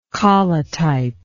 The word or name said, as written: collotypy